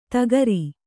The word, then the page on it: ಧಕಾರ: dhakāra